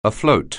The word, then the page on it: afloat: anglais